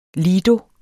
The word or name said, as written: lido